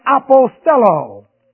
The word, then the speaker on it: apostello: There's another word that is used, and that is apostello. A-P-O-S-T-E-L-L, long O. Apostello.